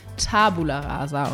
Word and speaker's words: Tabula rasa: Ähm, die äh, haben uns nämlich jetzt darauf hingewiesen, dass wir Tabula eigentlich völlig falsch aussprechen. Das heißt nämlich Tabula rasa und nicht, ich weiß nicht, wie wir es uns gesagt haben.